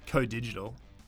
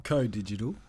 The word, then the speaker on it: Coh Digital: Yeah, you so I guess before we get going, we need to mention our partner, our digital partner, Co-Digital. The H is silent. It's actually spelled C-O-H Digital. – Co-Digital.